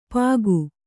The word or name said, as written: ಪಾಗು